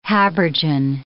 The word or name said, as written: habergeon